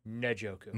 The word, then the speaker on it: Njoku: To get Njoku. – To tight Njoku. – And I like him. – By the way, it's Njoku. – Njoku, okay. – Not Njoku.